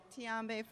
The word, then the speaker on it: Tiombe: Tiambe from Atlanta. – Okay, Tiambe. How to spell that? – T-I-O-M-B-E.